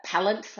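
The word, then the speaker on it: Pallant: I sent Matt Lieta a little note with a kind of aid memoir that it's palant like talent, siddle like middle, and assel like castle.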